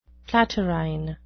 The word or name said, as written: platyrrhine